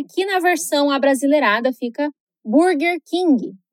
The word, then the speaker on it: Burger King: Burger King Burger King No inglês americano, que é o que você aprende aqui na Fluency, o R é sempre muito caipira. E em palavras onde ele aparece em duas sílabas diferentes, isso é ainda mais perceptível.